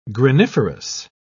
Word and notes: graniferous: British English

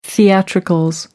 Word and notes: theatricals: American English

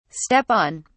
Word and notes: step on: British English

